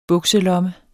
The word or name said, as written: bukselomme